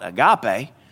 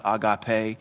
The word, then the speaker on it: agape: In the South, we call it agape, but it's pronounced agape.